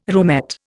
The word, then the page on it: roomette: Indian